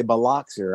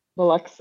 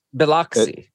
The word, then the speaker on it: Biloxi: Biloxi. – Huh? – Biloxi. Like UX, yes, is how how we natives call it.